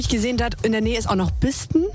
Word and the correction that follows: Bisten: Biesten. – Die ganzen Ortschaften durch. Was kennen Sie noch? Das heißt Biesten.